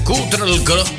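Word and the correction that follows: Cutral Có: ¿Lo digo bien? Ahí está bien, sí, más o menos, sí. Un abrazo grande a la gente de Neuquén, República Argentina. – Todos tus éxitos se escuchan aquí por la 92.5. – Beso grande por ahí, por como siempre ha ido la tía Gladys, a la gente de Cutralcó. Ahora sí lo dije bien.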